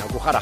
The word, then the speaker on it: Okuhara: Ahora lo he dicho bien, Okuhara, japonesa.